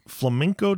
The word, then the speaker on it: flamenco: And I'll tell you what, the flamenco dress, flamingo, I know I'm saying that incorrectly. – Flamingo. – Flamingo. – No, not flamingo. It's not the bird. Flamenco. – Flamenco, yeah. – Flamenco. Okay.